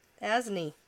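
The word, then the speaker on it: Asne: Yeah, that's how they say it. Yeah. Asni.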